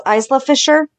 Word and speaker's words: Isla Fisher: It says, oh, I don't know how to pronounce her name. It's Isla Fisher? – Yeah.